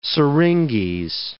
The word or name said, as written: syringes